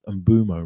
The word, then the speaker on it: Mbeumo: I've been told it's pronounced Mbeumo rather than Mbeumo.